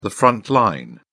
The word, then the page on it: front line: British English